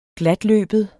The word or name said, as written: glatløbet